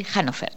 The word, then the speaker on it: Hannover: ¿Se dice así? Hannover. – Exacto.